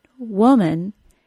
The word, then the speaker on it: woman: The other U sound, of the word woman, has no simple phonetic pattern in English. That sound is the same vowel sound as the words put, P-U-T, and good, G-O-O-D.